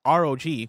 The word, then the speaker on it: ROG: Zaxxon Galaxian says, ROG is pronounced like ROG, where you say each letter separately.